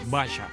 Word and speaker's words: Basia: Este es el primer éxito que yo conocí de Basha. Basha Shechelevska, se escribe Basia, con B y con S.